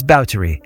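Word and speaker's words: Báthory: And just a note, if you've ever read about Elizabeth, you might have assumed her surname, spelled B-A-T-H-O-R-Y, is pronounced Bathory, but the proper period correct pronunciation is Bowdre.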